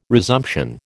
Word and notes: resumption: American English

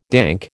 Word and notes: dank: American English

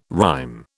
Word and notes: rhyme: British English

